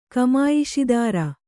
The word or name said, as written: ಕಮಾಯಿಷಿದಾರ